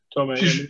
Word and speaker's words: Tomei: Marissa Tomei. Am I saying that right? – Tomei.